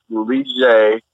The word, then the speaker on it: Lige: And I'm like, no, it's Lige Dusable.